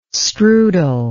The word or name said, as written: strudel